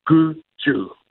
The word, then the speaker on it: Gudgu: And it's pronounced Gudgu.